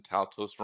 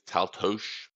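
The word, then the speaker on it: Táltos: And for what it's worth, we've been pronouncing Taltos wrong. Stephen Twist would say it's Taltosh because it's Hungarian.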